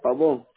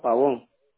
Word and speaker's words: Pavón: Lady Mendoza Pavón.